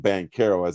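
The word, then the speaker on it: Banchero: And he said the CH is like cherry. And he said he asked, was a security guard at Chase. He said he asked Paolo that. And he said, yes, that is the correct pronunciation. So it's like cherry, Bonchero.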